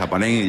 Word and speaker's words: APAHEM: la H no suena, ¿verdad? – No, buenas tardes. No suena, simplemente es APAHEM y ya está, y la H está ahí precisamente por la hematología de la sangre